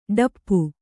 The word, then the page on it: ಡಪ್ಪು: ḍappu